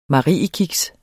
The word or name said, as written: mariekiks